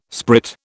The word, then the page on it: sprit: British English